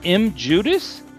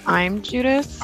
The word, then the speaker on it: I'm Judas: I'm assuming this is how you say the name of the band, M. Judas? M. Judas? – I think it's I'm Judas. – I'm Judas. Thank you, Winters. That sounds better.